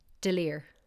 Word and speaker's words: D'Olier: For my non Irish listeners, D'Olier Street's in the middle of Dublin and it is spelt D apostrophe O L I E or. So maybe you think it's like Dollier Street? It's Delir. What about Chum?